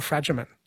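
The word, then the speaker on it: Fragomen: Did I say that right, Fragerman or Fragerman? – Fragerman.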